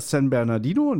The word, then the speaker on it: San Bernardino: Habe ich es falsch ausgesprochen? – San nee, Bernardino, hast, ne? Nein, du hast es richtig ausgesprochen.